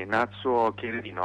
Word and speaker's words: Natsuo Kirino: Allora, eccoci qui, prima stavamo presentando Natsuo Kirino, innanzitutto è questa la pronuncia giusta? – Sì, Natsuo Kirino,